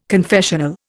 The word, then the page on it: confessional: Indian